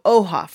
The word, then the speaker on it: Oha: My name is Ohamanda Lopez, so Oha for short. It's only two syllables, O and Ha.